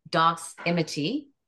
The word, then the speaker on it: Doximity: So stocks, but in the medical industry, you wanted me to look at a company called, I hope I'm pronouncing it correctly, Doximity. – Yep.